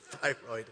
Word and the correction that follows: Thyatira: Wow. Thyatira. I said that wrong.